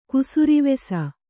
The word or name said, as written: ಕುಸುರಿವೆಸ